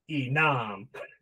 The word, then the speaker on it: Enam: Well, I mean, if I had you pronounce Enam, just emphasize Nam like all the time, which is pretty easy to do, I guess.